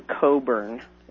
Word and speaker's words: Cockburn: It's called Dangerous Liaison by Andrew Coburn. It's spelled C-O-C-K, Burn, Cockburn.